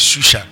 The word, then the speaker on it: Shushan: It's Shushan.